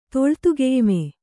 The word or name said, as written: ತೊಳ್ತುಗೆಯ್ಮೆ